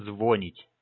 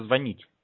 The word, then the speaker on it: звонить: Вот смотри, некоторые говорят, вот большинство россиян почему-то говорят звонить. Это ж неправильно тоже. Надо звонить.